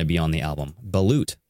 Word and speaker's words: Balut: B-A-L-U-T, right?